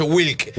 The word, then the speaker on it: Wlk: El paraguayo Wilk. El hombre sin vocales. Es WLK. – Y se pronuncia Wilk, pero es WLK.